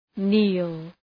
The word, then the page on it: kneel: ni:l